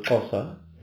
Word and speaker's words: Xhosa: You see, if you pronounce the word Xhosa, that's how it's pronounced. That is the click sound of it.